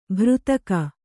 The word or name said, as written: ಭೃತಕ